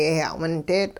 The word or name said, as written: yehya'windeetL'